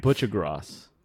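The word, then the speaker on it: Buccigross: like John Boogie Cross. I'm not sure if I'm pronouncing his last name. – Butchagross. – Yeah.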